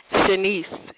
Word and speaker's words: Shanice: Shanice. – S-H-A-N-I-C-E.